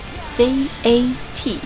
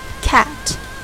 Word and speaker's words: cat: D O G D O G 读作 dog dog or dog dog 第二个词是猫 C A T C A T cat cat a 张大嘴 a cat 我们把这两个单词换到 it's a 后面，它是一只狗，我们就可以说 it's a dog。